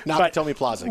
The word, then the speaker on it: Nakatomi Plaza: I didn't want to mispronounce it because I saw. No, it's okay. Nakatomi Plaza. – Yes. Yeah, Nakatomi. Well, I wanted to add an extra syllable.